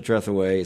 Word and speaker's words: Trethewey: You begin your book by talking about four people, Paula Deen, James Clyburn, Natasha Trethewey, is that how you say her name? – Yes.